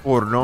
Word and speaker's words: porno: Porno non va pronunciato con la O piccola così. – Porno. – Ma con la O bella piena, bella larga.